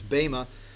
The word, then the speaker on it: Bema: It's actually the word Bema in Greek. It's an eta, not an epsilon. Not that you care, but epsilon is a short e and eta is a long e. So, it's Bema.